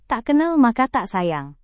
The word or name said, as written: tak kenal maka tak sayang